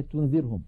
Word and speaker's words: تنذرهم: أمثلة على إخفاء النون الساكنة والتنوين: أنفسكم وقد سبق شرحها، تنذرهم، لاحظوا على الشاشة كلمة تنذرهم نون ساكنة بعدها ذال، ننتقل من التاء إلى غن إلى مخرج الذال، ولكن لا ننطق ذالا بل ننطق من الأنف غنة تنذي، فإذا انتهى زمن تلك الغنة نطقنا ذالا مكسورة مجردة عن الغنة.